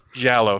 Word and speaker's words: giallo: Giallo films? – Giallo films.